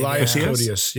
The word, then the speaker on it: Elias Koteas: Am I saying that right? Elias Koteas? – I think it's Elias. – Elias, I believe. – Elias Koteas, yeah.